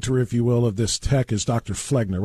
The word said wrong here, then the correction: Felgner: His name is actually pronounced Felgner. – Felgner, yeah.